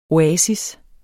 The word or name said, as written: oasis